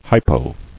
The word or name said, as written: Hypo